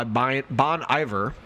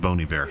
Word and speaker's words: Bon Iver: First off, Hey Ma by Bon Iver. – It's actually pronounced Bon Iver.